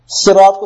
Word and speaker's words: صراط: پہلا لفظ ہے رجل، آدمی، رحیم، رحم کرنے والا، یوم، دن، صراط، تلفظ بھی سنیں، سین نہیں، صراط، صاد سے.